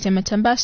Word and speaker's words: Sematimba: Okay, so you can either type my name out, Sanyu Sematimba, so double S-A-N-Y-U, S-E-M-A-T-I-M-B-A, but my Instagram name is Just Another African, but you don't spell it like how you say it.